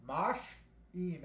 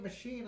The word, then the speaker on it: machine: I was like, Mosh-een. They're like, no, you idiot, machine.